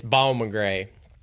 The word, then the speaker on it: Bowman Gray: You know, half-hearted at best, at least in my case, but to adequately pronounce Bowman Gray as the locals pronounce it, it's Bowman Gray. It's like Bowman Gray. Going out to Bowman Gray. Also how the great Burt Myers pronounces it.